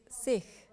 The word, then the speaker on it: Sikh: Point one Sikh, which is pronounced Sikh in Punjabi, is a word which means a learner or a disciple.